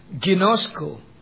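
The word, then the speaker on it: ginosko (γινώσκω): The most commonly used word is pronounced ginosko.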